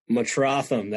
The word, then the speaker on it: Metrotham: Is it Metrotham? Metrotham, that is correct.